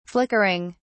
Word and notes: flickering: British English

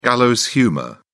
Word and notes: gallows humour: British English

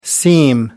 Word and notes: seam: American English

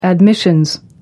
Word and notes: admissions: British English

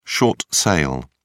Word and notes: short sale: British English

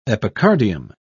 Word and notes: epicardium: British English

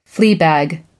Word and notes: fleabag: American English

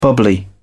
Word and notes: bubbly: British English

